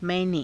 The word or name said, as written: mai~ne